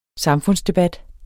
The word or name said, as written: SAMFUNDSDEBAT